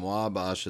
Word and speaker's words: Wabash: Speaking of which, growing up near Chicago, I always heard the street name Wabash as rhyming with ash rather than osh.